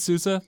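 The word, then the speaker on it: SUSE: Well, Sousa? Is that what you said? – I said Sousa. That's what I've heard. It's German. That's all I know.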